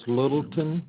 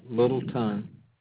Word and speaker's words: Littleton: Yes, Lanny, L-A-N-N-Y. And my last name is Littleton. – Okay, spell it for him. – L-I-T-T-L-E-T-O-N, Littleton. And my wife's first name is Linda. – You said L-I-T-T-E-O-N? – L-I-T-T-L-E, Little, and then T-O-N, Littleton.